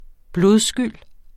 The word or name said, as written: blodskyld